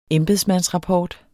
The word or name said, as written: embedsmandsrapport